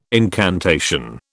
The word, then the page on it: incantation: British English